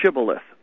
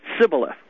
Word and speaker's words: shibboleth: It's the pronunciation of the word, that SH sound, was not in the dialect or the language of the tribe that had attacked them. They would say just the long S, the S, Sibboleth.